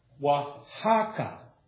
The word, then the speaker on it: Oaxaca: Our Bibles are being sent to Oaxaca is how you pronounce that. – Wow. – Even though it's O-A-X-A-C-A, but it's Oaxaca, Mexico.